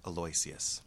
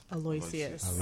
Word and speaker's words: Aloysius: How do you pronounce that track name? – Aloysius. – Aloysius.